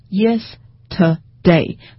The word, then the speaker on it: yesterday: 那么我再给大家一个例子，就比如说像昨天 yesterday，那么你把 yes 升起来读，那应该读成 yesterday。